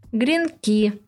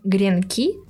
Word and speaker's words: гренки: По результатам портала «Где ударение .рф» в русском языке есть две нормы – гренки и гренки. Первый вариант постепенно исчезает из речи и предполагается, что он скоро исчезнет из словарей. Раньше единственным правильным вариантом считался гренки.